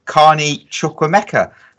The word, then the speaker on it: Carney Chukwuemeka: And that is Villa star Carney Chukwuemeka.